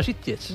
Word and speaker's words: Sitges: Pero esto es una película que viene de ganar un premio en Sitges, ¿mm? Y que para los aficionados de... – ¿Qué bien lo has dicho? – ¿Así? Sitges. No ha dicho Sitges, ha dicho Sitges.